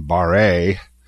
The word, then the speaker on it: Barre: Barr, or as Ian sometimes jokingly put it in the French pronunciation, Martin Barre.